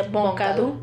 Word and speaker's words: Boncadu: Se escribe Boncadeau, algo así. – Boncadeau, ajá, y se pronuncia Boncadu.